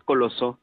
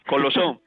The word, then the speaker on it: Colosó: Solamente el pueblo que es Colosó. – Colosó. Ah, con acento en la O.